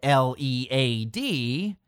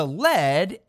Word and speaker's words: Led: Balloon evolved into Zeppelin and lead, L E A D, into lead, L E D, to dissuade people from incorrectly pronouncing it lead.